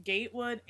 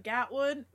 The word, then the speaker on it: Gatewood: I've heard it pronounced Gatewood and Gatwood, but it's spelled G-A-T-E-W-O-O-D.